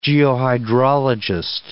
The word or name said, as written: geohydrologist